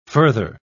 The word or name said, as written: further